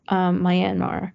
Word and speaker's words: Myanmar: I always, for some reason, say it wrong, but Myanmar.